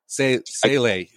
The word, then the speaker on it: Yabusele: You know, here I thought I was saying it correctly. You know, Yabusele all these years. And no, it was Yabusele. Sele. – Sele. That's what they were saying. Sele. Yeah, there you go.